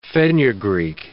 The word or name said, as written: fenugreek